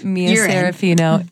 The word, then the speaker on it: Mia Serafino: We have Mia Serafino in the house.